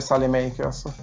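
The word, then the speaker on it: Saelemaekers: ho ascoltato come, come si pronuncia Sale Makers. – E si dice Sale Makers.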